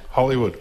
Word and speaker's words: Hollowood: Hollywood.